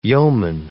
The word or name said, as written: yeoman